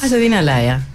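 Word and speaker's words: Azzedine Alaïa: Azzedine Alaia.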